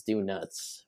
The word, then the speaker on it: Do-Nuts: I guess the full name is Do Nuts, which, as you might have... – Well, it's spelt that way, Do Nuts. – So, but it is it is donuts, though, right?